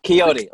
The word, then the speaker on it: Quixote: How do you say it? Quicksock? – For Quicksocks. No, Quixote, like Don Quixote.